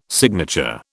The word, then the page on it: signature: British English